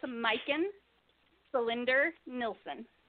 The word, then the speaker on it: Majken Selinder Nilsson: It's Majken Cylinder Nilsson.